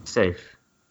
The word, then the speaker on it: Saiph: How do you pronounce that? Safe? – Let's see. – Safe. – S-A-I-P-H. – Safe. – Sipe? Sipe? Let's go safe. – Safe. Safe. – Sure. – It's safe.